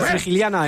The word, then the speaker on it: Frigiliana: Bueno, confirmamos, Frigiliana en Málaga, sí.